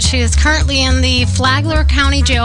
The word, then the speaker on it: Flagler County: This is, I don't know if I'm going to say this right, Flagler County? – Yeah, Flagler County, yeah. Okay. You got it.